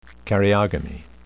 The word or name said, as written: karyogamy